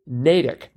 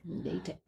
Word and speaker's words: Natick: And Randall writes, how to pronounce, and I will now pronounce it correctly, Natick. Natick.